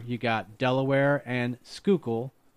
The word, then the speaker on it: Schuylkill: You got either the Delaware and the Montrose, or you got the Delaware and the Schuylkill? Am I pronouncing it? Schuylkill. – Schuylkill, thank you.